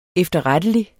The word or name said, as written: efterrettelig